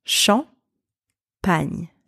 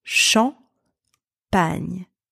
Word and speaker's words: Champagne: So let's see the pronunciation of champagne. I'll break it down for you. Two syllables. Champagne. Repeat after me. Champagne. Champagne. So first syllable, the letters C-H-A-M are pronounced champ.